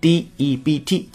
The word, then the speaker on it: debt: d e b t 字母 b 呢是不发音的，不是 debt，而是 debt，debt 非常好，这是第一件事儿啊，削减高额债务，cutting high debt。